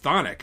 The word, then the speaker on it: Chthonik: Because that's actually how it's pronounced. It's pronounced Thonic. And it's like, no. C-H-T-H-O-N-I-K.